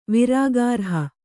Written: ವಿರಾಗಾರ್ಹ

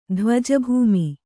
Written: ಧ್ವಜಭೂಮಿ

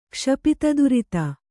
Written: ಕ್ಷಪಿತದುರಿತ

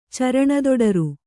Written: ಚರಣದೊಡರು

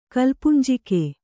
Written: ಕಲ್ಪುಂಜಿಕೆ

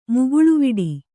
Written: ಮುಗುಳ್ವಡೆ

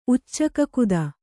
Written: ಉಚ್ಚಕಕುದ

